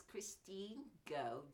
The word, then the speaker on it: Christine Goh: My name is Christine Goh, G-O-H is my surname.